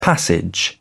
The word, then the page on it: passage: British English